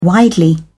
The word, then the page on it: widely: British English